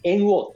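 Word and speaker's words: 猿桜: 猿の桜って書いて猿王って読むんだけど。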